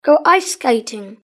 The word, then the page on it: go iceskating: British English